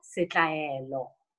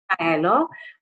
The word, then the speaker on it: Sitlaelo: Okay, so I am Samantha Sitlaelo. Oh, okay. Okay, so it's it's not Spanish. Um, yeah, Sitlaelo, but I'm okay with going by the name Sam.